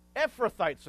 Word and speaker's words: Ephrathites: And they were Ephrathites, and you got that one too.